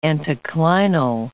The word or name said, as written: anticlinal